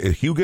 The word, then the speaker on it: hygge: Hygge, is that how you pronounce it? Mm-hmm.